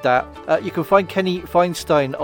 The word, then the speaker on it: Feinstein: And hopefully that's the right way to say it. It's not Feinstein, but I think it's Feinstein. F-E-I-N-S-T-E-I-N.